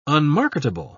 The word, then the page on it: unmarketable: British English